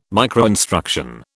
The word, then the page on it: microinstruction: British English